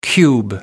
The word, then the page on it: cube: American English